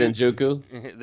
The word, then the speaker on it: Njoku: David Njoku? – Thank you.